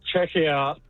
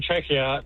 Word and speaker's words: Czechia: It's Czechia, but if you ask anyone from Czechia, they'll just call it Czech Republic.